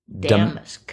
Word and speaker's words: damask: 39 across, fabric for some formal table linens was damask. – That's how you pronounce it? Damask? – That's how you pronounce it, yes.